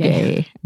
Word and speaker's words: rugae: rugae. Uh, am I pronouncing that? Properly? Yeah, rugae. Yeah, R U G A E or GG.